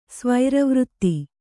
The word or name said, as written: ಸ್ವೈರವೃತ್ತಿ